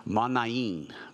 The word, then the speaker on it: Manaen: I had to look it up to see how to pronounce it. It's Manaen, who had been brought up with Herod the Tetrarch, and Saul.